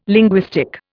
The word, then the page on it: linguistic: Indian